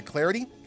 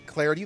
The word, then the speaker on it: Clardy: Follow me on Twitter at Troy Clarity. At Troy Clarity, the last name is spelled C-L-A-R-D-Y.